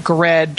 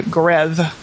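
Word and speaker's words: Goredd: When I first was in seventh grade, and I called it Gred to rhyme with Fred, right? But I have changed my own pronunciation of it to Greth. I had the double D. I didn't understand this in seventh grade, that the double D was its own sound.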